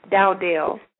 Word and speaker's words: Dowdell: Dowdell.